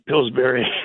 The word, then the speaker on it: Pillsbury: Pillsbury.